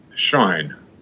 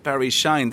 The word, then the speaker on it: Shine: I will be Barry Shine. – Barry Shine. That sounds like an Irish name almost. Have you Irish connections, Barry? – No, but it was often pronounced Shin. When I was growing up.